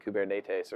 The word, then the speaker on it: Kubernetes: We actually pronounce it wrong. I think if you're actually Greek, you pronounce it something like Kubernetes or something like that.